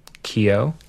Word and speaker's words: Keogh: Fort K-E-O-G-H. How would you pronounce that? – Keogh? – Keogh. Fort Keogh, Montana. – But I might be wrong. – That sounded better than what I said.